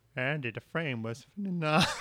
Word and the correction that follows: phenomenal: I think you're putting an extra syllable in there. Phenomenal.